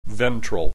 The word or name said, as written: ventral